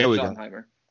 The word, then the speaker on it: Schottenheimer: I was gonna say the curse of Marty Schottenheimer or however you pronounce his last Schottenheimer. Name. Yeah, Schottenheimer.